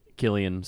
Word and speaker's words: Cillian: Killian, sorry. I always get my... – It's pronounced Ajishak. – My Irish pronounced... – Fige. – Murphy. Pronounced my Irish wrong.